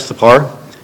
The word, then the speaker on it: Thapar: So to answer your question, Judge Thapar, did I pronounce that correctly? – Yes, you're perfect.